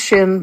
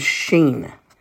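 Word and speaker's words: sheen (שין): It's not pronounced shin, but it is sheen.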